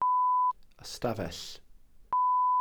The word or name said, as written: ystafell